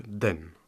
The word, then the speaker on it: 等: alors c'est au taille Attendre se prononce donc den en chinois. C'est ça ou pas? – C'est ça.